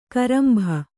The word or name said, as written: ಕೊರಂಬೆ